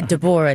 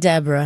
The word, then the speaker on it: Deborah: Yeah, Deborah, not Deborah, honey. Okay, this is Whitley Gilbert Wayne's child. She was not naming her no Deborah. Deborah, three syllables.